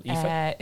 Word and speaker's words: Aoife: Or if any listeners have suggestions for stuff that they've seen that might pique Aoife's interest, what's your Twitter handle, Aoife? – It's at Aoife McVeigh, but my name is spelt very different to how it's pronounced. So it's A-O-I-F-E.